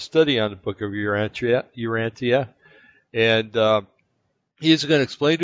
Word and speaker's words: Urantia: So they pronounce it Urantia. – Okay, Urantia.